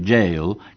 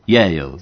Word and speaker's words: Yale: Es una palabra, que un vocablo que los argentinos raramente pronuncian bien, porque suelen decir Yale, que quiere decir cárcel. – Ajá, claro. – No, es Yale.